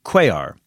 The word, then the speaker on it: Cuellar: C-U-E-L-L-A-R. So, Cuellar. – Angelica Guerra Cuellar. – I think it's Angelica Guerra Cuellar. – What did I say? – I think you pronounce the L's. I don't think those are silent.